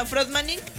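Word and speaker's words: Fröttmaning: Como suena, Frotmaning, pero con doble T.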